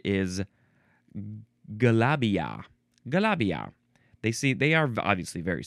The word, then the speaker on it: galabia: Now we have the word Galabria. Galabria. You can spell it G-A-L-A. Oh, no, actually, Galabia. There's no R. Galabia. G-A-L-A-B-I-A or G-A-L-A-B-I-E-H or G-A-L-A-B-I-Y-A.